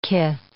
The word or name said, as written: kith